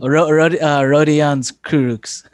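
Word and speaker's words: Rodions Kurucs: The Nets traded to the Rockets Caris LeVert, Rondé Cruces. I always say his name wrong. – Rodion's Kurux.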